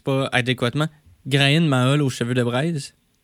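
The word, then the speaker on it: Gráinne Mhaol: Oui, c'est Grand Owl, en fait, c'est en irlandais. Mais bonne prononciation, mais c'est tough à prononcer. L'irlandais, ça ne se prononce pas en tout comment c'est écrit.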